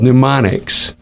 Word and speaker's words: mnemonics: It's called mnemonics. Mm-hmm. Spelled M-N-E-M-O-N-I-C-S. The M is silent. The E is long.